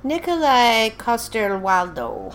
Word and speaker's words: Nikolai Coster-Waldau: Nikolai Coster-Waldau.